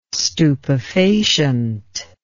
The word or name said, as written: stupefacient